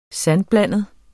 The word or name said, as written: sandblandet